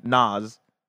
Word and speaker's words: Nas: Oh, like with a Z at the end. Nas. All right, cool. – Nas.